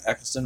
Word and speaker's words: Eccleston: Am I saying his name right, Eccleston? – Yeah, yeah Christopher yeah, Eccleston. Yeah, Christopher Eccleston. – Yeah, in 2009, really should go back and explore, you know, the older episodes.